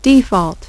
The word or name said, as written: default